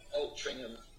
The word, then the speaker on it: Altrincham: Altringham Ja.